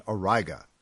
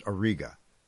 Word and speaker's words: Auriga: This is the alpha star in the constellation Auriga, or sometimes it's pronounced Auriga, which you can make out as a broad, misshapen hexagon with Capella at its top.